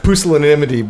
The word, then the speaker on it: pusillanimity: Pusillanimity, but it's best, you could just say smallness of soul.